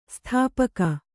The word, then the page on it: ಸ್ಥಾಪಕ: sthāpaka